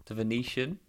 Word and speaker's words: Venetian: The Venetian.